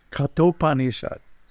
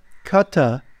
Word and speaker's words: Kaṭhopaniṣad: Kaṭhopaniṣad. You have to pronounce the T properly. It's not Kata, it's Kata. Kaṭhopaniṣad.